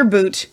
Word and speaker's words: Bout: Victor Boot, his name, it's spelled Bout, by the way, but they're saying Boot, was exchanged for Brittany Griner, the WNBA athlete that mm-hmm.